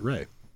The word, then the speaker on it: Rhea: But that's R-I-A. That's Rhea Perlman. – I thought it was R-H-E-A. Things to look up during the song. – But also, is it pronounced Rhea or is it Ray? – I always thought it was Rhea.